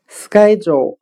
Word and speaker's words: schedule: 我们可以用谐音法来记忆这个单词 schedule，日程表、时间表。它的发音很像汉语的“ 是该走”，是否的“ 是”，应该的“ 该”。